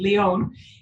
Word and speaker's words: Leon: is Angie Leon. – It oh my goodness, I did it. – Spelled like lion, but that's okay.